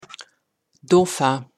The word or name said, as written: Dauphin